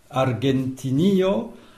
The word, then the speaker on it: Argentinio: Alors, Argentinio, mais tu es peut-être influencé par l'espagnol, parce qu'on dit Argentina. Donc, Argentinio, c'est vrai que c'est pas tout à fait la même prononciation, notamment la lettre G et puis l'accent tonique aussi, hein.